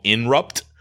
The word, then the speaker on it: Inrupt: So the new company that is backing his decentralized platform is called Inrupt. Not Interrupt. I didn't spell it wrong.